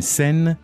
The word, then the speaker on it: scène: That's not the word, it's a scene, scène. – Right. – Une scène.